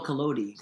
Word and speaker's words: Collodi: So the author, and I will put his name in right where I said it, Kodaly. – Carlo Collodi. – Thank you, Collodi. – I think it's how you say it.